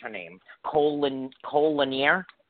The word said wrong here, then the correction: Chloe Lanier: Chloe. – She has the chops. Chloe Lanier.